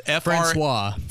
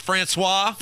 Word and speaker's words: François: I think it's François, but it's François. – François. F-R-A-N-C-O-I-S. That's François? – François, yes. – François, okay. – François Mitterrand.